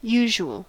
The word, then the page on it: usual: IPA: /ˈjuːʒuəl/